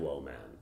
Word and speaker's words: WoMan: people don't always understand WoMan. Like, what it means, or like, how they mispronounce it, and they're like, wow, Woman. It's the words woe and man together.